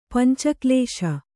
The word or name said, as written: ಪಂಚಕಳಶ